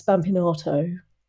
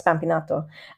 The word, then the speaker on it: Spampinato: And she said that the way that I pronounce, I'm going to say it deliberately badly now, but the way that I pronounce spampinato, that's the English way, but how I pronounce spampinato makes her understand that I know some Italian.